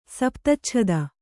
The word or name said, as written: ಸಪ್ತಚ್ಛದ